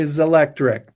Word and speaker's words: Zelectric: Well, that has been our guest of Zelectric. Am I saying that right? – I like to say Zelectric.